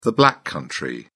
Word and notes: black country: British English

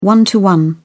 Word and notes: one-to-one: British English

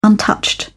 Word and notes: untouched: British English

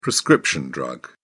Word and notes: prescription drug: British English

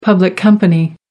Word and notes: public company: American English

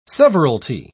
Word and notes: severalty: British English

